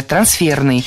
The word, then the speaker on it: трансферный: Трансферное, неверно, правильно, трансферное, трансферный. И здесь опять же шпаргалка, исходное существительное «трансфер». Оно французского происхождения, имеет ударение на втором, то есть последнем слоге «трансфер». И такое же ударение сохраняется в прилагательном.